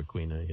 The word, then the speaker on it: Quina: Quina? – I always just say Quina or Quina, yeah. – Quina.